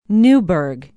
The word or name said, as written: newburg